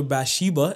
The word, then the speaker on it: Bathsheba: David, when he was lusting after Bathsheba, is that how you pronounce it? – Yes.